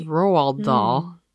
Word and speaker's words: Roald Dahl: But the original book was Charlie and the Chocolate Factory, a 1964 book by Roald Dahl, who I used to always think was Ronald, and it wasn't until I was like 20 that I was like, there's no N in there. – Right. – It's Roald Dahl. – The N is silent. – The N isn't there.